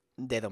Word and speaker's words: dedo: Dedo means finger. The letter D is actually pronounced by putting your tongue in between your teeth. Dedo, dedo.